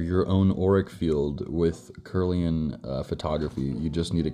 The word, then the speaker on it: Kirlian: It was called Kirlian photography, K-I-R-L-I-A-N. Am I saying that right? Yep. Kirlian.